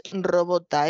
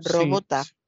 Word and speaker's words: robota: Sí, eh, procede, el, origen es robota, es la misma palabra que usted dice pronunciada de otra manera, robota.